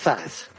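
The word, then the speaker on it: THAAD: We would be out of Thads. – Yeah. – Is that how we pronounce it? I don't know. – Thad missiles. – We got the Chad missiles. – They're Thad. T-H-A-D.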